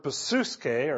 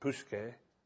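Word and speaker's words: psuche (ψυχή): The word used in the text here is the word psuche, or that's how you'd say it, psuche. It's spelled P-S-U-C-H-E in the English letters.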